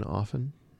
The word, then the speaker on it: often: Do you put the T in often? – Often. No, I do not.